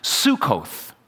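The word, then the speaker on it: Sukkot: And the name of this holiday in Hebrew, which you may actually come into contact with today, is pronounced Sukkoth but it gets translated as something like the Feast of Booths or the Feast of Tabernacles.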